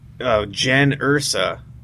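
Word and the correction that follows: Jyn Erso: Mispronounced the name at first, but that's okay. – Isn't it Jen? – Jen Erso. – Erso? Yeah. Whatever. Doesn't matter. – So he said Jan Ursa.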